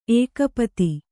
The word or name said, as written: ಏಕಪದಿ